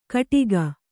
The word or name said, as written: ಕಿಡಿಕೆ